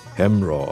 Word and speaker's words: Hamraw: Oder das Stück Hamraw, das klingt amerikanisch, ist aber eigentlich eine Erhebung, die bei uns vom Nachbarort Hemrein heißt, aber bei uns im Fronhäuser Platt in der Sprache Hamraw ausgesprochen wird.